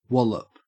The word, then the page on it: wallop: Australian English